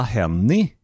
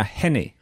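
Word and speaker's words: Henie: Och bara för att till slut klargöra det hela, det korrekta uttalet lär man sig i filmen, det är Sonja Hennie. – Ja, alltså, säger norrmännen. Eller Sonja Hennie, säger amerikanerna i filmen.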